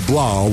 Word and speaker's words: Blough: Um, I'm going to say that my third and final Fulcrum football player is going to be David Blough rhymes with cow.